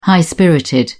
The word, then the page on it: high-spirited: British English